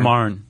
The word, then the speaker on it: Marne: Marne. Is it Marne? – The Marne.